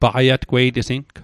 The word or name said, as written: paqaiatgweitesing